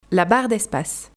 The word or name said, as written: la barre d'espace